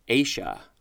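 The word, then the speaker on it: aceae: So the word is pronounced acia.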